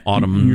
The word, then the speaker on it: autumn: I pronounce the N, autumn.